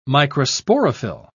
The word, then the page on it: microsporophyll: British English